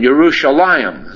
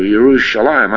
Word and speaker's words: Yerushalayim: Now, religious people, and we saw many of them when we were in Jerusalem, Yerushalayim, it's pronounced in the Hebrew, Yerushalayim. I've been going through these words to make sure they're right before we go there. Again, it begins with a Y and not with a J. It begins with a Yud, the letter Yud. Yerushalayim is properly pronounced. Yisrael and Yerushalayim.